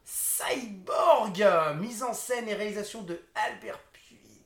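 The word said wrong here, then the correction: Pyun: Pune, pardon.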